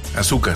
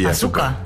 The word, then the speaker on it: Ahsoka: La pronunciaban como. Yo me recuerdo que era Ahsoka, ¿no? – Ahsoka, sí. Ahsoka.